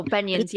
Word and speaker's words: beignets: You know the um I think they're called beignets. Beignets. Oh, beignets. – Yes.